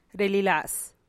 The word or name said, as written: rigix